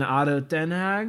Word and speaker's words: ADO Den Haag: He owns the Philadelphia 76ers, New Jersey Devils, Crystal Palace, FC Augsburg, Waslen Beveren in Belgium, and Ado Den Haag. Did I say that right? – Good As question. A pronunciation expert on the podcast, I think you got it right on the head.